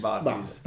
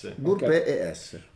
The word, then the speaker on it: burpees: Ah, vuoi sapere come si pronuncia? – Esattamente. – Barbies. Che non sono le Barbie. – Ok. – C'è una P in mezzo, o no? – Burpee S.